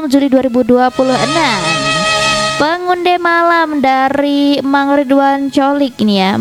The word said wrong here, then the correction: Cholik: Mang Ridwan Holik ya bacanya, C-H-O-L-I-K gitu, Holik ya.